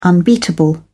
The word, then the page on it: unbeatable: British English